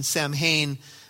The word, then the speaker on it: Samhain: And so they began to celebrate a holiday called, it's spelled S-A-M-H-A-I-N, Samhain. But as you read a little bit more on this, they say it's pronounced Samhain.